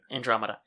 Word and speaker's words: Andromeda: Andromeda.